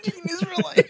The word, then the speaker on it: Israelite: Dean Israelite. – Isn't that how you say it? – Yeah, it is.